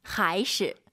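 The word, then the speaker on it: 还是: 하이시. – 이게 재밌는 게 하이시 아닙니다. 경성이에요.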